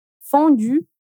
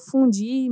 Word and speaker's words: fondue: Como a gente não tem esse som no português, a gente acaba pronunciando o som de I no lugar de U. Então, a gente acaba dizendo fundi, meni.